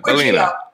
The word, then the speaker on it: Bellino: Bellino.